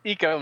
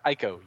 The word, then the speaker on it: eco: It is pronounced eco, not Ico.